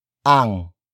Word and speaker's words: ang: In this example, we have sino followed by a focus phrase that is introduced by the ang marker. Sino ang tatay mo? Sino ang tatay mo? Sino ang tatay mo? It means, who is your father? Or, who's your daddy? Word for word, it's sino, ang, tatay, mo.